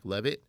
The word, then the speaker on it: Levitt: Jack Levitt.